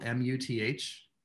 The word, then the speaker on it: Muth: So it's you know, my name is Jason and it's spelled Muth. It's like Ruth with an M.